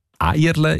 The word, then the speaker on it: Erle: Eierle ist laut dem Idiotikon eine typische Thurgauer Aussprache von Erle.